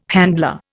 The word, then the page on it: handler: Indian